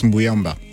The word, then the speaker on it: Mbujamba: monsieur Ça s'écrit Mbunjamba, mais ça se prononce Mbouyamba.